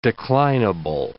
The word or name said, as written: declinable